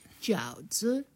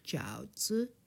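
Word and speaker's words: Jiao zi (饺子): called the jiao zi. – Jiao zi. – So yes. third tone. Jiao zi.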